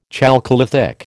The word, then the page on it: chalcolithic: American English